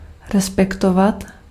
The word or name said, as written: respect